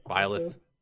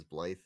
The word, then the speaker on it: Byleth: So the leaker that successfully told us about Byleth. I played this game for how many hours? I don't know how to say the guy's name. – It's Byleth. Byleth. I always pronounce it as Blythe, though.